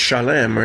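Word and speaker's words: Shalem (שָׁלֵם): So Salem in Hebrew is, of course, pronounced Shalem, or it also sounds familiar probably as Shalom.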